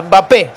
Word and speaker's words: Mbappé: como Mbappé, se pronuncia, y cuando la E del final no lleva tilde, como Laporte, no se pronuncia.